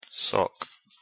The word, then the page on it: sock: English